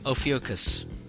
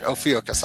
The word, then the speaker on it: Ophiuchus: Ophiuchus. – Ophiuchus, if that's saying it right. And I looked it up, that's right, Ophiuchus.